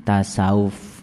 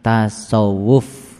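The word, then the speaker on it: tasawuf (تصوف): Padahal dibacanya yang benar itu adalah tasawuf. Ya. Jadi ada huruf ta di situ, kemudian ada huruf shod, dan kemudian ada wau, dan terakhir adalah huruf fa. Jadi ada empat hurufnya, ta, shod, wau, dan fa. Itu pakai tasdid ya di atas wau. Maka dibacanya menjadi tasawuf ya.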